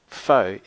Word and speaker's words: faux: The word faux is pronounced faux as in phony, it's a French word snobby people use that means fake or knockoff.